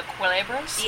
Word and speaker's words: culebra: Oh, the culebras? – Yes.